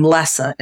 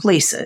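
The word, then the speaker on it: Lesa: I get thank you emails all the time from people who spell my name L-I-S-A and people who pronounce my name Lessa instead of Lisa. It's L-E-S-A, Lisa like normal, right?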